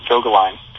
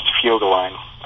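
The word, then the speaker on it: Vögelein: A friend of mine, Jane Irwin, who does a book called Vogeline. It looks like the word Vogeline with a V, but it's pronounced Fjogeline because it's a German word.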